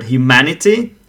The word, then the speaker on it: humanity: So we can have a look at the word humanity. Humanity, four syllables. And ma is stressed, which is the third syllable from the left.